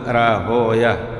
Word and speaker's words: راهويه: نعم، أول مرة جاء اسم إسحاق ابن راهوي، إسحاق ابن إبراهيم هو ابن راهوي، والمحدثون يكرهون ويه، كلمة ويه، لأنه كأنهم يقولون ويح، يعني يشير إلى ويح أو كذا، ولذلك دارقطني وغيرهم نقلوا أن المحدثين ينطقون راهويا سيبويا نفطويا، ليس نفطويا وكذا هكذا، ولذلك أصاب الشيخ أنه ضبطه راهويا. نعم، راهويا هكذا زيدوا.